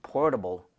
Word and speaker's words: portable: 그런데 발음할 때 제가 portable이라고 했나요? – 아니요. portable 했어요. – Yes. 자, T에다가 flap을 주세요.